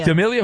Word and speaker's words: D'Amelio: D'Amelio.